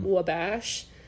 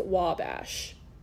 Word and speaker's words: Wabash: And I just wanted to come on and correct Trey because I think at one point he said Wabash. So that was not correct. It's pronounced Wabash. Wabash, Indiana.